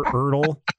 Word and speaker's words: Erdl: Dr. Erdl rhymes with turtle for the win. – Rhymes with Yertl the turtle.